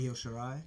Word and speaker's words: Io Shirai: Io Shirai.